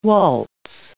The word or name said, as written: waltz